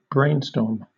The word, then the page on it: brainstorm: British English